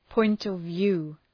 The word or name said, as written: point of view